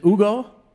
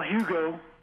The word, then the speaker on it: Hugo: On the line here from, is it Ugo, Oregon? – Well, it depends on where you're born, I suppose. – Yeah. – Drop the H. – How do you say it? – Well, Hugo.